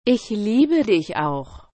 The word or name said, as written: ich liebe dich auch